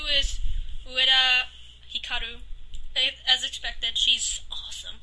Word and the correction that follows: Utada: Wait, you say Utada Hikaru? – Yeah. – She's amazing! – I see her name wrong all the time! – Oh my goodness, she did music for Kingdom Hearts and it's great. – It's Utada, right? – Yeah, Utada.